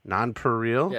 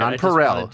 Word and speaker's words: Nonpareil: So, Resident Redacted's Copley, I don't even know how to say this one. It's like the candies, the Nonpareils. – Nonpareil? – Yeah. I don't know if that's right. – Nonpareil. Nonpareil, okay. According to Google.